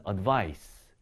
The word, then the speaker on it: advice: 뒤에 있는 c로 끝나는 것은 advice. 이 발음으로 끝나게 되겠죠.